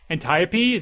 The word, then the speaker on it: Antiope: Antiope, is that how you pronounce her name? – I, I believe so.